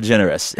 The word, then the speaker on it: DeGeneres: Ellen DeGeneres.